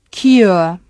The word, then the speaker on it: cure: Cure, otro triptongo, sure, pure, cure.